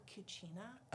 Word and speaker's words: Cucina: It's actually called Cucina. – Oh. – That is the Italian pronunciation.